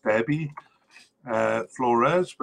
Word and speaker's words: Deby Flores: So I've heard it pronounced Deby Flores, but it's spelled Deby, and he's 27 years old and has been with the squad since 2015 with 26 caps.